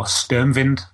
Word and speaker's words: Sturmwind: So with that in mind, you've got things like Sturmwind, or Sturmwind, as it's pronounced in its native tongue, German.